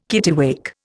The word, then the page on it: kittiwake: Indian